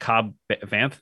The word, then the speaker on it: Cobb Vanth: I always met Cobb Vanth.